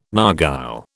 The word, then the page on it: narghile: British English